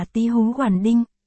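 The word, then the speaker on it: tí hú hoàn đinh (醍醐灌顶): Thể hồ quán đỉnh là một thành ngữ Trung Quốc, đọc là tí hú hoàn đinh.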